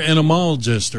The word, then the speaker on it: entomologist: Is it entomologist? – Entomologist. Thank you. Did I say it right that time? – I think so. – That was pretty close first time, but I think I really nailed it.